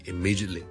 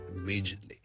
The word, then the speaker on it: immediately: 咁加埋個 ly 咧，我仲會聽到咧，immediately immediately immediately 個 t 咧都可以唔出現得，所以就 immediate 急停就可以 immediately immediately，